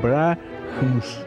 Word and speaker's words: Брамс: Фамилия да. Произносится Брахамс. – А, простите, Брахамс, да. Причем там, по-моему, через апостроф. – Да, Брахмс. – Как бы МС в конце.